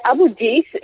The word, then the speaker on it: Abu Dis: Well, Abu Dis is just outside of Jerusalem, and he won't say who offered him, whether it was the Trump administration, whether it was Israel.